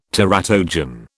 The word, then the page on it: teratogen: British English